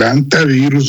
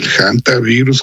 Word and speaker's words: hantavirus: El hantavirus o el hantavirus, que se dice así, sin problema, debemos aclarar que no es una nueva plaga, ni se comporta como el COVID.